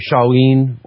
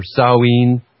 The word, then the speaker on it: Samhain: Samhain, pronounced Shawin or Shoin or Sawin or Choin, etymologically means the end of summer.